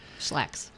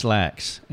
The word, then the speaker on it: Schlacks: This is a cooperative effort between her and one of her counselors on her team, Bill Schlack. Did I say that right? – Mm-hmm. – Schlack. – Schlacks. – It's Schlacks, and it's S-C-H-L-A-C-K-S.